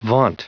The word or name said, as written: vaunt